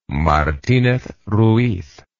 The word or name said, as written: martinez ruiz